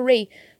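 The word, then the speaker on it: Wray: Eu acho que pronuncia Ray, porque tem um W ali que não serve para bosta nenhuma.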